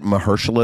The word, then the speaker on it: Mahershala: Mahershala is his first name? – Mahershala.